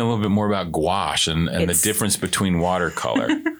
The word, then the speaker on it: gouache: Well, gouache is spelled G-O-U-A-C-H-E. – Okay. – Which is very confusing. – Yeah. – Yeah. So if you look at A-C-H. Gouache. E. Oh, you got to put the E on the end, too.